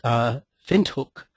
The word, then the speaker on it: Windhoek: In examples of these, the mark is actually pronounced Windhoek.